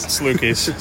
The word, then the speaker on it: Salukis: What got you to play in for the Salukis? I said that right, Salukis? – Yeah, Salukis.